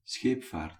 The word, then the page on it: scheepvaart: /ˈsxeːp.vaːrt/